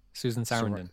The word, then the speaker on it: Sarandon: Sarandon. – Sarandon. – I've been saying that wrong for years. – Susan Sarandon.